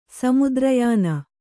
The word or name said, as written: ಸಮುದ್ರಯಾನ